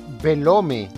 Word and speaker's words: Belome: Y ese personaje es eh, Belome, o por lo menos escribe Belome. se, puede pronunciar Belom, Belom. Yo, le decía Belome, ¿cómo se escribe?